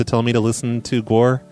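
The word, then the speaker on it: GWAR: I just wanted to say, hey, you should listen to Guar. They're probably one of the best bands to ever exist, period. – I know of them. – So, please listen to Guar. – It's pronounced G-War.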